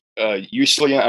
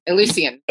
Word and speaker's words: Eleusinian: and it was where the Eleusinian, I'm pronouncing this wrong. – Eleusinian, yes.